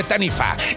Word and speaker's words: Taniwha: que se escribe Taniwha, pero se dice Taniwha.